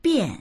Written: bian4

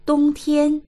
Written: dong1tian1

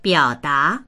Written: biao3da2

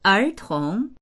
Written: er2tong2

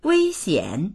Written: wei1xian3

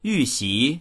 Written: yu4xi2

